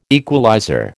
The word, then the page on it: equalizer: American English